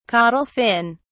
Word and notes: caudal fin: British English

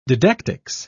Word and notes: didactics: British English

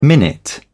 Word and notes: minute: British English

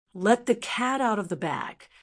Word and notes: let the cat out of the bag: American English

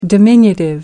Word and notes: diminutive: American English

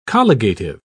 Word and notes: colligative: British English

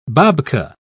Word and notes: babka: British English

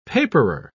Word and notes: paperer: British English

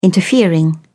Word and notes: interfering: British English

